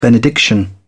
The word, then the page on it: benediction: British English